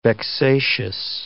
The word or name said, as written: vexatious